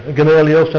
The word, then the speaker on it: Gamaliel: Is that right? Gamaliel? Gamaliel, thank you.